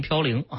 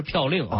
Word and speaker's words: 嘌呤: 长期不高啊，那这个我们还听到过嘌呤这个词儿哈、很多人把它读成嘌呤啊，这其实正音是嘌呤啊。 – 哦，那我们都读的不对了。 – 嘌呤啊，这个又是什么东西呢？ – 那么这个嘌呤啊，它是核酸代谢的一个产物。